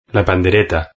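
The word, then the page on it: la pandereta: Spanish (Spain)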